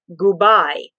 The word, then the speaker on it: gubai: And he would sign it, I'll spell it for you, G-U-B-A-I. Thinking, what the heck is that? And if you pronounce that phonetically in Italian, it was goodbye. No, goodbye.